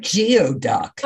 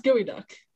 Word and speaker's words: geoduck: Looks like geoduck. – I know, but it's pronounced gooey duck.